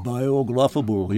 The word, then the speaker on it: biographable: it's a word I find difficult to pronounce. Biographable. – Biographable, yes.